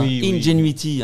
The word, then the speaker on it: Ingenuity: On s'envole sur la planète Mars avec Ingenuity. C'est comme ça qu'on prononce, hein. Ingenuity.